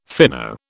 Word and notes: fino: British English

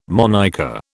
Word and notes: monniker: British English

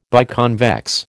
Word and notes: biconvex: American English

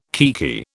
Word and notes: kiekie: British English

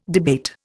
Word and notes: debate: Indian

